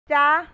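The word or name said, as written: จ๊ะ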